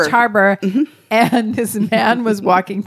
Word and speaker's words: Roche Harbor: Is that how you say it? – Roche Harbor. – Roche Harbor? – Mm-hmm. – Not Roche. Roche. Like, yeah. – You're not having a... – Roach. Richard Roach. – No.